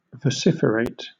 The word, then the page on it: vociferate: British English